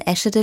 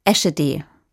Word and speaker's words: Eschede: Denn es ist so, alle, die in Eschede leben, sagen Eschede. Alle anderen sagen Eschede. Nach einer kurzen Phase der Unentschlossenheit habe ich mich für die hochdeutsche Aussprache entschieden.